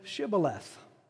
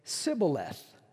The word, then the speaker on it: shibboleth: And if he said no, then they would say to him, "Well, then say Shibboleth." But he would say Sibboleth, for he could not pronounce it correctly.